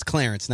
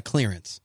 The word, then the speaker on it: Clarence: His name is Clarence, not Clearance.